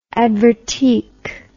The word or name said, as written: advertique